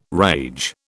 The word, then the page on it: rage: British English